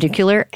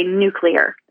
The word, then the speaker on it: nuclear: That said, most people, myself included, will say nuclear as opposed to nuclear.